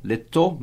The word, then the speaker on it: little: L-I-T-T-L-E, little, little, mas a gente pronuncia little, little, little mice.